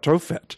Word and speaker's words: Tophet: But if you're complaining about Tophet, is the pronunciation, about Tophet, there's a reason for it.